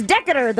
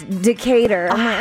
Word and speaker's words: Decatur: When I first visited Vegas as a kid, I'm like, look, it's Decatur. They're like, it's Decatur. Decatur. – It's a rite of passage to learn the correct way to say Decatur.